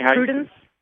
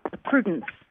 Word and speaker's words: Prudence: Prudence. – I heard a Susan. – Prudence. – How do you spell that? – P-R-U-D-E-N-C-E.